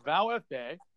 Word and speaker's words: VfB: Well, it's also, it's pronounced Vow FB and like Silas.